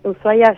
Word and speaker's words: Ushuaia: Y ahora hay otra introducción que nos preguntábamos un poco antes de establecer esta entrevista, es ¿cómo se pronuncia? ¿Cómo es este Festival Internacional de Cine de Montaña Ushuaia? Shh, así es. – Así es Ushuaia. – Shh. Así, más cortito, shh.